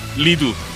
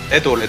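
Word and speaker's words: Redo: 레도 레도. 아, 오프닝 오프닝이죠? 네, 레- 리두라고 해야 되는 거 아니에요? 레도라고 읽어야 돼요. – 레도예요 레도? 레도 레도라고 읽던데요? – 그요 혹은 레도 맞아 리두 아니에요 레도라고 읽던 그 뭐냐 그 걔네가 불렀잖아요.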